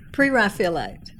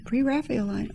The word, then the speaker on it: Pre-Raphaelite: Pre-Raphaelite. – Pre-Raphaelite.